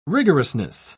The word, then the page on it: rigorousness: British English